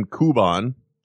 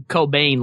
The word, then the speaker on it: Kouban: Yeah, like our good friend, I pronounce the name Kuban. – Oh, okay. – K-O-U-B-A-N. – I think I called him Cobain last time. – Yeah. – Which was not even remotely correct.